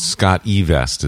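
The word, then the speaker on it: SCOTTeVEST: Well, it's Scott E. Vest is the way they actually pronounce it.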